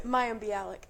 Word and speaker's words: Mayim Bialik: Mayim Bialik. – Mayim Bialik.